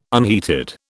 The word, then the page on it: unheated: British English